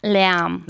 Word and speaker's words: Lärm: De Leam.